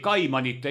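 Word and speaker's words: Cayman: Läbi Eesti filiaalide liikus oleks võinud samamoodi liikuda läbi filiaali Kaimani saartel või Austraalias või kas või seal samas Uusmeremaal. – Ja sa hääldasid õigesti, on see Kaiman või Keiman? – Keiman on Keila. – Keiman Island.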